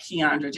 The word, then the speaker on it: Kiaundra: So you can follow me there at Kiaundra Jackson.